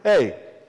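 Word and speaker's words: hey (ἤ): Literally, in the Greek, the word is hey. That's literally how you pronounce the Greek word, hey.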